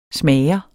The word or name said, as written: smager